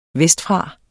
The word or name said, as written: vestfra